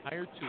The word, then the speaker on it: Hiretual: Hiretual.